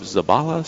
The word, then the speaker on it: Zabala: Of Zabala Cigars. Am I saying that right, Ricky? – Yes, sir. – Fantastic. – Z-A-B-A-L-A. – Excellent. It's just what it looks like.